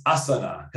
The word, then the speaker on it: asana: Apparently I learned that I'm mispronouncing Asana, which is what I think a lot of people say. If you're a yogi, my wife does yoga, it's Asana. Because Asana means flow.